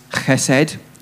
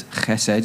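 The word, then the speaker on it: chesed (חסד): The next word is chesed, or some people pronounce it as chesed, but it's chesed, C-H-E-S-E-D.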